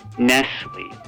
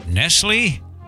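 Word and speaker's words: Nestlé: Nestle. – Why, how did I say it? – You said it with a T. – Nestle? – It's pronounced Nestle.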